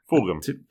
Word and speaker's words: Fulgham: Is it Fulgham? Is that how you pronounce that? I've heard people pronounce that different ways. – Fulgham. – To me, it's... Yeah, Fulgham. Okay.